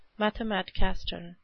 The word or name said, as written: mathematicaster